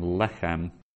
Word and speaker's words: lechem: And then lechem, the accent is right to the upper left of the lamed, so lechem.